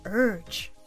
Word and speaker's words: urge: 例えば、私は強い衝動に駆られたというときは、 I felt a strong urge. – 今日はこの urge を言ってみましょう。 – Repeat. Urge. Urge. – 発音のポイントは口をイの形にしてウと発音、そこに R の音をつなげる意識でやってみましょう。